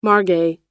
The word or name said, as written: margay